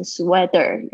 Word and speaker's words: sweater: 但你不要念成 sweater。有些同学呢，我跟他讲说，这个声音有点，有一点要需要有一点技巧，它好像接在 t 跟 d 之间的那个发音的位置 sweater。